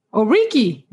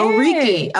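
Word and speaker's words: oríkì: Tell me how to pronounce this. Is it Oriki? – Oriki. Yeah. – Oriki. Okay. So since the last time you were here, I have introduced myself differently because I've been reading your book.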